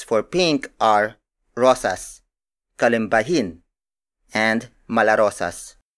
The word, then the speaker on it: kalimbahin: Its Tagalog counterpart is kalimbahin.